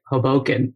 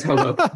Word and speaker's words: Hoboken: Like most, I'd say two-thirds people say Hoboken, but then some people choose to emphasize the second syllable and say Hoboken. So but I think it's Hoboken.